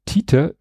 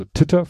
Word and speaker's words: Titer: Dann Aussprachehilfe Titer wie T analog T-Shirt kommt von Titration.